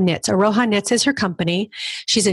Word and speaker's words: Aroha: Aroha, yeah.